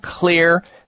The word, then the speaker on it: Xlear: And that hope you need to be aware of comes through potentially for you in something called clear nasal sprays. Clear is spelled with an X for xylitol, X-L-E-A-R, clear nasal sprays.